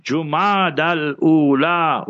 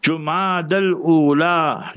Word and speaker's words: Jumada al-Ula: It's Jumada al-Ula with a Dhamma on the Jim, not a Fatha Zabar on the Jim. Jumada al-Ula, Jumada al-Ukhra, or Jumada al-Akhira, both is fine.